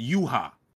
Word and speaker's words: Yhwach: Spelled weird, but it's they pronounce it Yuha. I was taking close attention to that shit. It's Yuha, they pronounce it. But they spell it like Y-H-W-A-C-H.